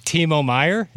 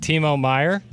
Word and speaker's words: Timo Meier: You have an article written yesterday about, and I'm just going to butcher the name here, but Timo Meier. Did I get it? – Yeah, you nailed it. Yeah. – Yes, Timo Meier, that could be a good fit, and there's some interest there.